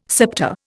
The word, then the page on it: septa: Indian